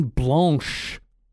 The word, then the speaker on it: blanche: I'm drinking an apricot lemon blanche. That's correct French pronunciation for everybody out there too.